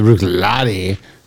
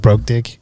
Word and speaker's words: Bruichladdich: The CH is silent, by the way. – That's right. The Bruichladdich. It's just basically ICH is E. Bruichladdich. – So it's not like broke dick?